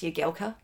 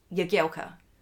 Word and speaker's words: Jagielka: I say, it's pronounced Jagielka. – you can't help yourself. – And they look at the little wife and go, I'm sorry, what? And I'm like, Jagielka. And then they would obviously start laughing and go, why do you think it's pronounced like that? And then you then say, because he told me.